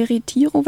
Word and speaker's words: Retiro: Ja, nachmittags hatten wir dann wieder etwas frei, waren im Park de Retiro, wenn ich das richtig ausspreche, der Spanier neben mir.